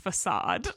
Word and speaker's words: facade: I believe it's pronounced facade. – No, that doesn't sound right because there's like a C and it makes a K sound.